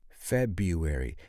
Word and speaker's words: February: At least in the United States, the way you will hear it pronounced is February. February. That's kind of strange, a silent R. We have a lot of silent consonants in the English language, but a silent R is very rare.